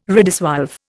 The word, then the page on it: redissolve: Indian